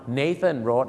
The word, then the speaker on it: Nathan Road: Nathan Road